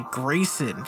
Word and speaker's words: Gr3ys0n: Next up is Gohan by Grayson. That is spelled G-R-3-Y-S-0-N.